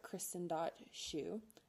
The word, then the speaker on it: Kristen Shew: Lind.sed or Kristen.shew, S-H-E-W for shoe.